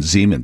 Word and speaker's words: Zeman: Chief Zeman will join us a little bit ago. I think I gave her a hard I pronunciation a moment ago. Kristen Zeman, the chief of police for the Aurora Police Department.